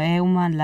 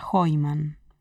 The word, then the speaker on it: Heuman: O, y bueno, y después mi apellido, que es Seuman, con una H al principio que bueno, Euman, la H es muda en, general en Argentina, puede ser Euman, pero le pueden llegar a decir Heuman, y en realidad la pronunciación correcta del apellido sería Heumann, porque es un apellido, supongo, vendrá, no sé si de los alemanes o de los austríacos,